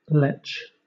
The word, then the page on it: letch: British English